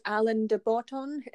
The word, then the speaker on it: Alain de Botton: I read this book recently by this guy called Alain de Botton. – Nice pronunciation.